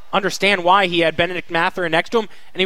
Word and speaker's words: Mathurin: Oh, Benedict Mathurin, yeah, from Arizona.